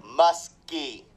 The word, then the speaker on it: Muskie: Muskie. – M? – M-U-S-K-I-E. – Okay. – D-O-U-C-H-E is how you spell it.